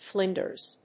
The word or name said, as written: flinders